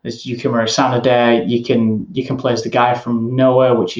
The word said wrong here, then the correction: Nioh: I'll just quickly point out, you're saying Noah, but you mean Neo, right? Yeah. – I don't know how it's pronounced. – It's just, N-I-O-H, Neo.